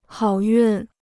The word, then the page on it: 好运: hǎo yùn